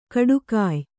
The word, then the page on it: ಖಡಗಾಯಿ: Kannada